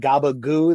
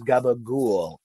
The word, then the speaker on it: gabagool: And then also, in the chat, I see someone saying Many Saints of Newark has me yelling gabagoo the entire week. Okay. The correct pronunciation is gabagool. Okay. Which I guess for people don't know, that's capicola, ham capicola, but we say it very, very poorly.